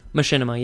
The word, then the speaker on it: machinima: It is specifically taken from the game Halo, but in terms of the, how'd you pronounce it? Machinima? – Machinima, yeah. – Right. – That's how I've heard, like, two best friends and other people pronouncing it.